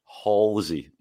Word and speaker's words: Halsey: Halsey, Mark Halsey.